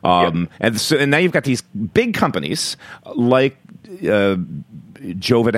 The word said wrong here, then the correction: Givaudan: how do you pronounce it? Givaudan, which is the biggest flavor company.